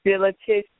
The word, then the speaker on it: Philetus: P-H-I-L-E-T-U-S. L-E-T-U-S. Philetus, Philetus, and Hymenaeus.